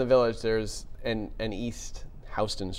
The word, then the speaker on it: Houston: Lagrange turned a Houston boogie band into the biggest groups on the planet.